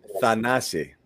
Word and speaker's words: Thanasi: Let's give everybody the correct pronunciation on it. Thanasi. Okay? – Just call him the brother.